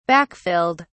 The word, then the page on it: backfilled: British English